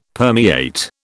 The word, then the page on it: permeate: British English